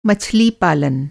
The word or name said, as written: मछली पालन